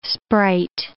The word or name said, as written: sprite